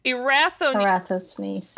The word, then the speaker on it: Eratosthenes: Eratosthenes.